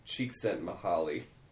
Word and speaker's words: Csikszentmihalyi: Incidentally, I want to say that I figured out how to say this guy's name correctly. It's Mahali Csikszentmihalyi.